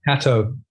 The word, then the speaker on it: Catto: Catto.